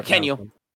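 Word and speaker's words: Mackenyu: Makenyu, is that how you say his name? I'm so bad. – Makenyu.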